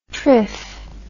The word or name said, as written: trif